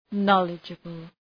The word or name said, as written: knowledgeable